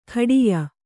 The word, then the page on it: ಖಡಿಯ: kannada